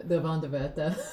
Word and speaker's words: Wanderwörter: I've only ever written like Wanderwörter, like wonder words. – I feel like I would lean into the Wanderwörter pronunciation just because it's...